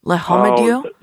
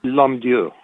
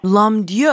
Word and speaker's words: L'Homme-Dieu: It's French. Le Homme-Dieu? – L'Homme-Dieu. – L'Homme-Dieu. All right, Ezra L'Homme-Dieu.